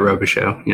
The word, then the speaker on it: Robichaud: We are here with Ryan Robichaud. Did I say that right? – Yeah, you got it, Robichaud.